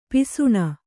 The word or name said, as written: ಪಾಸಾಣ